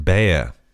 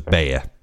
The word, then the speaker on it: Bea: Is it not pronounced Bea? – Is it? – I think it is.